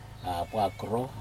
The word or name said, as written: apo akro